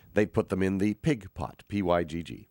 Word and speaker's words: pygg: They're named after pig, which is spelled P-Y-G-G.